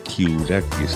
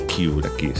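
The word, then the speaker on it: Karukas: un teclista fantástico de Washington D.C., Greg Kiourakis, que se pronuncia así, aunque se escribe Caroukas. Apareció en algunos discos que era así, Kiourakis se pronuncia.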